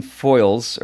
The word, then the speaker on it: Foiles: It's a new book by Jonathan Foiles, or I think is how you say his name, F-O-I-L-E-S.